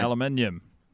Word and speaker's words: aluminium: I screw up the Kiwi pronunciation of that word every time I say it. I've been trying aluminium. – There you go.